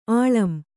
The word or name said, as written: ಆಳಂ